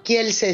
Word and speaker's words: Kielce: Pues hoy nos vamos a visitar una población que se escribe Kielce y que los judíos llamaban Kielce, ¿no? – Kielce, sí. Kielce. Bueno, Kielce es el nombre polaco, digamos, y los judíos que solían yiddishificar los nombres de las localidades rusas o polacos la conocen como Kielce.